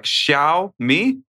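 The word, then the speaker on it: Xiaomi: And he goes, but it's basically pronounced as Shiao with a flat tone and me. I don't understand. How do I say this word? – I think a high flat tone. So like Xiao Mi? No, like Xiao Mi.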